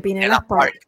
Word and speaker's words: Pinellas Park: Ahí está Fernando Zamora desde Pinellas Park, Florida. Lo dije bien. – Muy bien, Pinellas Park.